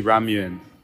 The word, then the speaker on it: ramyun: We couldn't do the Korean episode without the coup de grace of Korean instant nudes, and that's the kimchi ramyun. That's a ramen with a Y in it, so you have to pronounce it right.